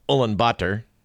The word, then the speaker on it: Ulaanbaatar: It's actually pronounced Ulaanbaatar.